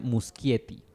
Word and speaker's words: Muschietti: Sí se pronuncia Muschietti. Ya que él es argentino.